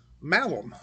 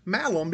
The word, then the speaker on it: malum: And so he would have known that apple in Latin is malum. Malum, if I'm saying that right. M-A-L-U-M.